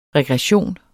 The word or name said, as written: regression